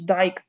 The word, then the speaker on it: Dicke: Oczywiście gwiazdą jest tutaj Darrell Dicke bądź Dyke, zależy jak kto go wymawia, ale tak ostatnio usłyszałam, że jednak ci trenerzy, którzy go prowadzili za dzieciaka, mówią Daryl Dicke, więc niech będzie.